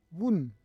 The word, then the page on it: vun: Roon